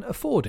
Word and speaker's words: afford: The stress is on the second syllable, so the AF at the beginning is weak, and you say afford.